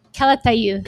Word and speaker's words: Calatayud: And can you pronounce this correctly? Are we doing Catalud? – Calatayud. – Calatayud.